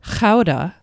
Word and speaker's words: Gouda: Gouda, or Gouda, as it's pronounced in the Netherlands.